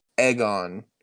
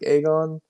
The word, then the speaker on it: Aegon: Egon. Like, they're really pushing the egg pronunciation, and some people still say it enough that it's almost like Aegon, And um, it's confusing.